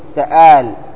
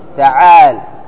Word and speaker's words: تعال: تآل تآل خطأ، تعال ها ما تقول تآل، قل تعال.